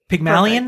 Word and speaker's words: Pygmalion: But it starts with, in ancient Greek mythology, Pygmalion, perfect. Is that, am I saying that right? – Yep.